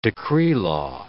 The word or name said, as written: decree-law